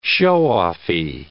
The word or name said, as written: show-offy